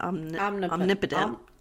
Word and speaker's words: omnipotent: How do you say that? Omnipotent. – Omnipotent.